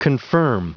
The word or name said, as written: confirm